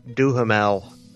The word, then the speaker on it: Duhamel: If my knee, while I was trying to get through a crowd to get a free champagne, if my knee brushed Josh Duhamel, Is that how you pronounce his name? – Yeah.